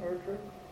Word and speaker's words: Erdrich: And is that how you pronounce the name, Erdrich? – Yeah.